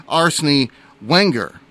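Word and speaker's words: Arsène Wenger: And correct myself on the pronouncing of the coaching great, Arsene Wenger was his name there.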